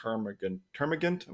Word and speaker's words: termagant: You mentioned like the lowliest termagant. Am I pronouncing that correctly? – Yeah.